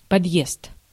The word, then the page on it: подъезд: /pɐdˈjest/; podjezd